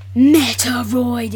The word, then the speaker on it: Metroid: Instead, they showed Metroid, if I'm pronouncing it right. – You are.